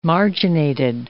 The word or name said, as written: marginated